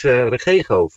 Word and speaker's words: Regego: Zeg ik het goed eigenlijk? Regogo? – Nee, het is Regego.